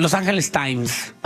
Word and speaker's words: Los Angeles Times: según Los Angeles Times, este periódico que aquí en Estados Unidos, Los Angeles Times.